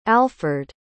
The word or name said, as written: alferd